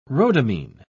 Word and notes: rhodamine: British English

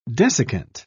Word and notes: desiccant: British English

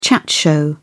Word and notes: chat show: British English

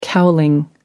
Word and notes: cowling: American English

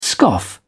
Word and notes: scoff: British English

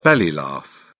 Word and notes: belly laugh: British English

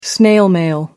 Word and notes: snail mail: American English

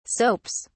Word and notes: soaps: English; British English

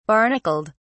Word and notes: barnacled: British English

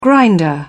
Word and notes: grinder: British English

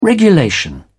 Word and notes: regulation: British English

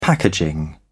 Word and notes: packaging: British English

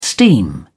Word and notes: steam: British English

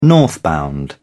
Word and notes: northbound: British English

